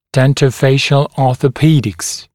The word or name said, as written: dentofacial orthopedics